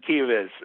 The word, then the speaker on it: Kives: And I'm like, Michael Kievitz, K-I-V-E-S. He's from CAA. That's his name. And Kievitz is how you pronounce it.